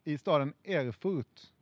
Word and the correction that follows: Erfurt: Mm, bra uttal. – Tack. Eller det var alltså inte bra uttal? – nej, Erfurt.